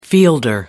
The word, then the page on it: fielder: American English